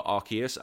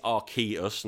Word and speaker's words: Arceus: Everyone's already thinking DLC for Arceus. Apparently it is Arceus, not Arceus, as I've been pronouncing it for so long. Apparently they confirmed it's Arceus.